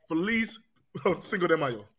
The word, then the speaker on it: Feliz Cinco de Mayo: Feliz Cinco de Mayo.